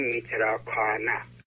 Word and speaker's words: میتراکانا: اما مهرگان، یا اگر بخواهیم به تلفظ باستانیش بگوییم، باید بگیم میتراکانا، یکی از بزرگترین جشنهای ملی ایرانیان است، یعنی دقیقا بزرگترین جشن ملی ماست پس از نوروز یا در کنار نوروز.